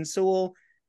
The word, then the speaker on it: Seoul: How do you pronounce the city? S-E-O-U-L? In Seoul? – Yeah, Seoul in Korea. – Seoul, Korea.